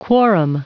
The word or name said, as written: quorum